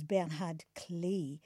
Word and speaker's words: Bernhard Klee: I mispronounced his name before. It was Bernhard Klee.